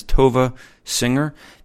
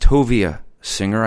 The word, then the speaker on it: Tovia Singer: So one of the things I need to correct off the bat is you're going to hear throughout, I'm going to refer to him as Tovia Singer. That is not his name. It is properly pronounced Tovia Singer.